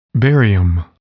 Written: barium

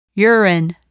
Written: urine

